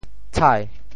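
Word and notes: 倸: tshai2; Teochew

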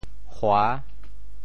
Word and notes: 禍: hua6; 文

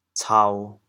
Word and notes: 剿: cao1; 潮阳

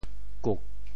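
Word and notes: 榾: kuk4; Teochew

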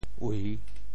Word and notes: 謂: ui3

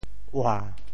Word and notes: 嚄: ua7; Teochew

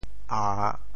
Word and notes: 詏: a3; 潮州

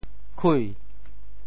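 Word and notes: 晷: kui2; Teochew

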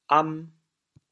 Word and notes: 飲: am2; 潮阳

